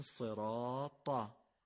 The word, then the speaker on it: صراط: به جای این که بگیم صراط ت، باید بگیم صراطا.